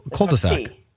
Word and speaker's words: cul-de-sac: No, you say cul-de-sac. – No. Cul-de-sac. – There's no T. It's just C-U-L. Cul-de-sac.